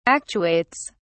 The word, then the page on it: actuates: British English